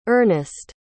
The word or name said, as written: Ernest